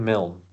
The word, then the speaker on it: Milne: Milne? – Milne? – Yep. Christopher Robin Milne.